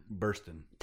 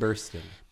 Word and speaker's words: Burstyn: Ellen Bernstein is in it. I cannot say her name. – Burstyn. – Thank you. – Ellen Burstyn. – Thank you. – Ellen Bernstein. – I cannot say it. The Y throws me off.